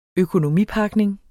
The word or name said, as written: økonomipakning